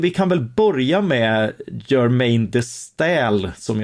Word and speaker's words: Germaine de Staël: Ja, alltså Germaine de Staël, det är inte lätt att uttala, men så ska det uttalas.